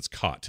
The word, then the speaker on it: Chott: Much to my surprise, I saw a name I recognize, Bill Chott, Cott is how you'd say it. The H is silent.